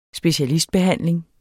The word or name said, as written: specialistbehandling